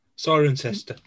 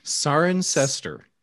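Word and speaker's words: Cirencester: Sirenster. – Sirenster. – Sirenster. It should start with an S, really, but it doesn't.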